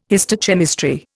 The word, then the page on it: histochemistry: Indian